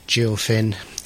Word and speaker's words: Gil Finn: Jill Finn. – Spelled with a G. – Again, a play on the fishing theme. – Yes. – Of Gil Finn.